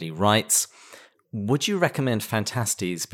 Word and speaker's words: Phantastes: There are multiple different ways to pronounce Phantastes, but the majority of MacDonald scholars do pronounce it Phantastes.